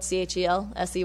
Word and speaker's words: Chelsey: Because my name is spelled Chelsey, and that's always something that I have to kind of correct.